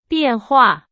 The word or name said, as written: 变化